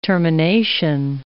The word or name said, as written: termination